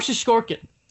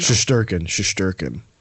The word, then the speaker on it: Shesterkin: So, Igor Shesterkin. I know I didn't say that right because, you know. – Shesterkin.